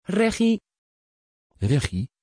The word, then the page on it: reggie: Dutch